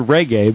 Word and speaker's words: reggae: And the word reggae is actually the word reggae, but in Portuguese you pronounce the leading R as if it were an H.